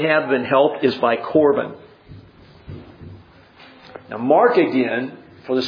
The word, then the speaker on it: Corban: If you can say Corban, you pronounce the Hebrew word.